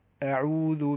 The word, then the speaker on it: أعوذ: فإذا الاستعاذة نحقق الهمز فيها، أعوذ بالله من الشيطان الرجيم، أعوذ، حتى يعني يكون هذا الدعاء العظيم وينطق بشكل صحيح.